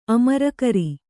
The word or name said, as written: ಅಮರುಗಾರ